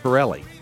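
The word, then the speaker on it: Pitrelli: It's P-I-T-R-E-L-L-I, but the T is silent. Pirelli.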